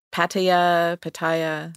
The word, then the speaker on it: Pattaya: And I don't know if I'm saying that correctly. Pattaya. – Yes.